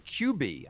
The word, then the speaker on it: Quibi: Quibi.